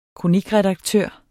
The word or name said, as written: kronikredaktør